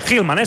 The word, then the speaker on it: Hillman: Hillman. – Hillman, s'ha de dir. Val, és que no sabia si era Hill o Hull.